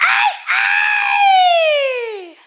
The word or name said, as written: Cabaret